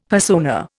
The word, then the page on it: persona: Indian